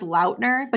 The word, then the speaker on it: Lautner: One, it's pronounced Lautner, but he never corrected anybody.